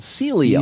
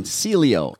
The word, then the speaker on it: Sileo: It's called Sileo. Sileo. – So write that down. Sileo.